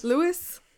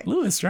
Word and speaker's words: Lewes: Cape May Lose Ferry. L-E-W-E-S. Lewis? Lewis? – L-E... Wait, spell it again? – L-E-W-E-S. – L-E-W. – Lewis. – Lewis? – Lewis. – Lewis. L-E-W-E-S? – Yeah. – Yeah. – Okay. – Lewis, right?